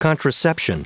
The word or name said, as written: contraception